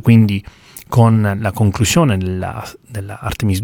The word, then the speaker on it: Artemis: E in generale l'architettura Artemis, io mi leggo alla pronuncia americana perché vivo a Houston, sono abituato a pronunciarla in questo modo, dico Artemis non perché lo preferisca, sono abituato a sentirlo pronunciare Artemis.